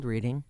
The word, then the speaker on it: Reading: Well, it's spelled reading.